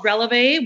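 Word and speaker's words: Relevé: It's called Releve, which you pronounced it right.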